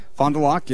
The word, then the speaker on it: Fond du Lac: Fond du Lac. – Fond du Lac, yeah.